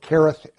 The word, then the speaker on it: Cherith: So the Lord makes a special provision for the prophet, and he sends him to this one location, the brook Cherith, or the brook Cherith, as I think it's properly pronounced.